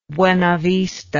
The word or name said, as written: buena vista